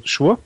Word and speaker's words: Szczur: Der wird geschrieben SZCZUR. Also Schur. Schur. Sure. Sure.